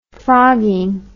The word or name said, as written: frogging